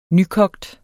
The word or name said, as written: nykogt